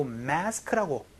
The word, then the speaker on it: mask: 영어로는 발음이 마스크가 아니고 mask라고 매 소리가 나니까 요거 조금 주의해 주시면서 연습 한번 해보도록 하죠.